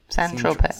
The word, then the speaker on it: Saint-Tropez: How do you say it? – Saint-Tropez.